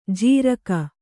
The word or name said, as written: ಜಾರಿಕೆ